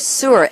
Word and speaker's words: Sur: And we're delighted to welcome in just a few minutes, Sanchita Sur, S-U-R.